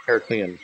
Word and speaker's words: Heraklion: And how do you say it? Is it Heraklion? – Heraklion, thank you.